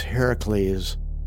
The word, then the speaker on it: Heracles: After ages had passed, Zeus relented and allowed his son Hercules, pronounced by the Greeks Heracles, to shoot the eagle, thus finally freeing Prometheus from his torment.